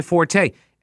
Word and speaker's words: forte: Some such and such is not my forte. And then it turns out it's fort. You pronounce it fort.